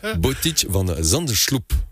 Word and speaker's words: Botic van Zandschulp: Je sais que tu m'attendais là-dessus. Botic van Zandschulp. – Eh bien, avec la prononciation et tout, félicitations.